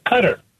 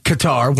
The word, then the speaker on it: Qatar: When I was a kid, it was Qatar, but apparently now it's Cutter.